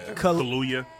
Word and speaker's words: Kaluuya: Yo, Daniel Kaluuya is kind of getting into, like, that's how you say his name? Did I say his name? – Kaluuya. Yeah.